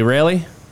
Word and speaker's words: Wraley: Wraley? – Wraley, like Ray of Sunshine. – That is really good name. Like that. Anyway, we have Jeff Wraley,